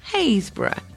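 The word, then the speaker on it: Happisburgh: The ancient people left their footprints at Haysborough, pronounced Haysborough, but spelt Happisburg.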